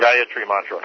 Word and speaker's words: Gayatri Mantra: Gayatri Mantra.